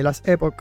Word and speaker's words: Last Epoch: Eh, se llama, estoy buscándolo, el Last Epoch, creo que se llama. Eh, se pronuncia, perdón, eh, las, eh, las Epoch.